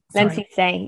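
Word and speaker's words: Lemn Sissay: is it Lem Sissi? – Lem Sissi, yeah.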